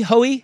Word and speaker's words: Hoey: It's either Hoey or Hui. But it's H-O-E-Y. Yeah. It's like Joey with an H. – Rudy Hoey? – Rico. – Rico Hoey. – Rico Hoey.